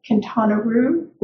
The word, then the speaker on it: Quintana Roo: Quintana Roo, that's how I heard it's pronounced.